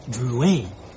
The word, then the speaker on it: Drouet: It's pronounced Drouet. Our family was French on my father's side.